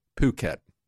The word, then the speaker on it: Phuket: Even when you get off the coast of, it's actually pronounced Phuket in Thai.